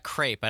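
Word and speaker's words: crêpe: In French, we would say crepe. – Crepe. – The S at the end in French is silent, but in English or American, it'd be fine to say crepes. Crepes.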